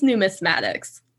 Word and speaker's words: numismatics: So how did you become interested in numismatics, and what is numismatics? – I'm very impressed, that you pronounced it correctly.